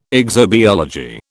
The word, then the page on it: exobiology: British English